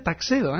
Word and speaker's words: Tuxedo: Sí, Tux es la abreviatura de Tuxedo, Taxedo, se escribe Tuxedo, pero se pronuncia Taxedo, ¿eh?